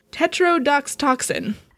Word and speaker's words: tetrodotoxin: also known as tetrodotoxin. – I was going to say it how you know how to pronounce it. – Tetrodotoxin. – Sure. Sounds right to me.